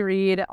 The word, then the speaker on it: Read: Just Holly Reed, R-E-A-D, like read a book. I know there's a lot of ways to spell read, apparently. but just think read a book.